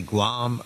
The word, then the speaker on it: Guam: Guam or the Hawaiian Islands or all the way home to the U.S.